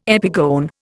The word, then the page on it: epigone: Indian